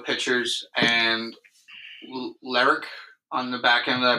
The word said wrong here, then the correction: Leclerc: Jose Leclerc. – Leclerc. – Yeah. Leclerc. That's definitely. – That, yeah, that's good.